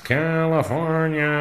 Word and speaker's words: California: I'm not surrendering to anyone who can't pronounce California. – Gray, I hate to tell you, it's a Spanish word. It's pronounced California.